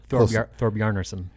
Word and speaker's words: Thorbjarnarson: And, you know, Thorbjarnarson has been one of the guys. I know I just probably butchered his last name. – That's close. Thorbjarnarson.